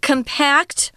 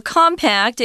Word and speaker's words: compact: This word compact or compact, it can be pronounced either way. Uh, the British only say compact if it's an adjective, but it just means something that's arranged in a really tight space.